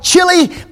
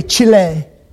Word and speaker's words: Chile: Men from South America started coming to class, and the gospel spread to Peru, Colombia, Argentina, and we used to call it Chile, but now you have to say it correctly, Chile. Isn't that right?